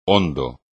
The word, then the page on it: hondo: espagnol